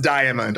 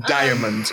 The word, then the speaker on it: diamond: It is pronounced diamond. I want to say right now, they do pronounce it diamond in the show. I love it every time they say it. All the vowels.